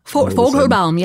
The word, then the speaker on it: Fogelbaum: Fogelbaum, yeah, that's the technical German pronunciation, so.